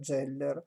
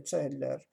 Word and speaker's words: Zeller: che in italiano si pronuncia Zeller, il più delle volte in maniera erronea, la corretta interpretazione, la corretta pronuncia sarebbe Zeller.